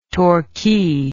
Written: torquay

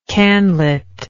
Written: canlit

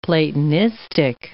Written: Platonistic

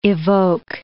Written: evoke

